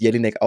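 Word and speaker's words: Jellinek: Und hier wird zitiert aus der sogenannten Drei-Element-Lehre des Staatsrechtlers Georg Jelinek mit Doppel-L, also ich glaube, das spricht man mit Jelinek aus, was quasi einen Staat ausmacht überhaupt.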